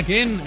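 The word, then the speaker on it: Kean: Pues ahí está otro golito de Mois Ken, porque se pronuncia Ken.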